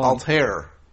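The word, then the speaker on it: Altherr: Can we get a pronunciation check first of all? – Altair. – Aaron Altair, right.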